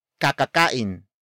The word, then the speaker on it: kakakain: For example, kakakain lamang ni Bert ng gulay. Kakakain lamang ni Bert ng gulay. Kakakain lamang ni Bert ng gulay. It means Bert just ate vegetables. Word for word it's kakakain, lamang, ni, Bert, ng, gulay.